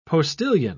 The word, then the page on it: postilion: British English